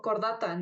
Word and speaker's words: Chordata: Pues es como cordata, ¿no? Que se escribe chordata o...